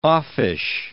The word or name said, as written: offish